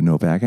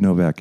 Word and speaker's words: Novak: and that would be under David Novak Actor. And on Instagram, same kind of stuff. You can follow me, David Novak Actor, or David Novak Podcast. Somebody had the other name already. So anyway, and Novak is spelled N-O-V-A-K.